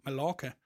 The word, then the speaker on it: Mallorca: Og vi ville tage til Mallorca, som i retteligt udtales Mallorca,